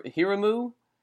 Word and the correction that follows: Hiromu: Takahashi? Is that how you say it? Hiromu, yeah.